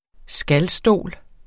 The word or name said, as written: skalstol